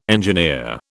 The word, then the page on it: engineer: British English